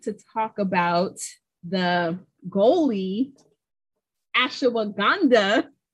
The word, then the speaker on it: ashwagandha: Am I saying it right? – Ashwagandha. I believe I looked at the pronunciation. I believe that's correct.